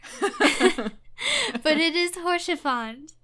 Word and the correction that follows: Rochefort: Orchifant? – Rochefort. – Rochefort? Yeah, that guy.